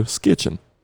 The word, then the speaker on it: skitching: How do you spell it for me? – S-K-I-T-C-H. – Sketching. – And. I-N? – Apostrophe N? – Yeah, one of those two. Skitching.